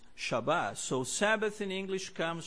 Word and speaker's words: Shabbat: the Hebrew root for the word Sabbath is would be transliterated as S-B-T, but it's pronounced Shabbat, right? Shabbat.